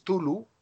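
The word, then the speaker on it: Cthulhu: Parece ser que es Tulu, pero antes de la T en una especie de C aspiada. Sería correctamente, sería Tulu. Sin llegar a pronunciar la A de Catulu.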